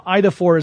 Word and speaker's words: Eidophor: Now, IDA4 is spelled E-I-D-O-P-H-O-R, and technically, if you're being kind of generous, it means image bearer, roughly speaking.